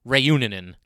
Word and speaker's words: Reunanen: I, I, think they, I think I was missing a syllable when I would talk about him in the past. I believe they were saying Reunanen last night, and of course he got his first NHL assist.